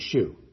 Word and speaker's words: shew: Show, S-H-O-W, they spelled it S-H-E-W, and they pronounced it shoe.